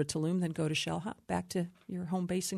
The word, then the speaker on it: Xel-Há: Xel-Há. You spell it X-E-L-H-A.